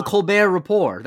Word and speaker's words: Colbert Report: It was called the Colbert Report. That was the joke. Since Colbert is silent, he then called it the Report because the T's also silent there.